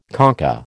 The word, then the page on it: concha: American English